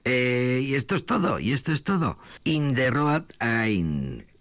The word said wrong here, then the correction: On the Road Again: que ahora Norberto me corregirá en la pronunciación. No, bien, on the road, ¿qué he dicho pues? On the road again.